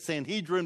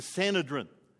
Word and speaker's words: Sanhedrin: Sometimes people say it Sanhedrin, but I think the correct pronunciation is Sanhedrin.